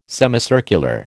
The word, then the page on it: semicircular: American English